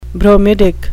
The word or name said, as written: bromidic